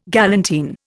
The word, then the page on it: galantine: Indian